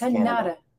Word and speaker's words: Kanata: I actually have relatives in Kanata, and I know it's pronounced Kanata. Canada.